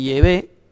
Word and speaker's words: Yahvé: El nombre del Dios Padre, porque el Dios Padre tiene un nombre personal, parece ser que la pronunciación más probable es Yahvé, parece ser en hebreo arcaico, pues dice que va a estar en sus frentes.